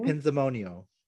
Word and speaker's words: pinzimonio: Pinzimonia. Am I saying that correctly? Pinzimonio? – Pinzimonio.